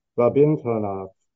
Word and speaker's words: Rabindranath: Rabindranath, I think the accent's on the second syllable there.